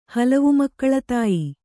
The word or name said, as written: ಹಲವುಮಕ್ಕಳತಾಯಿ